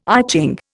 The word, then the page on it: I Ching: Indian